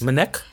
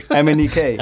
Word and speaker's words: MNEK: Manek? Sorry. – MNEK. – MNEK. – MNEK. No. – Don't worry. Everyone was pronouncing his name wrong at the beginning.